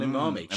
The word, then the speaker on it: umami: It gives to an umami. It's an umami. Cheese is an umami thing. What? – Umami? – Is that how you say it? – Umami. – Umami, thank you.